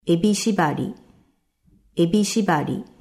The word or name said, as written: Ebishibari